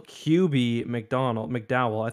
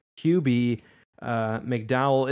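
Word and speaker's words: Kube-McDowell: Michael Cuby McDowell. I thought it was Michael Cube or Cube, but Michael Cuby McDowell.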